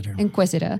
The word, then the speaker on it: Inquisitor: Well, where's the stress in this word? – Inquisitor. Second syllable.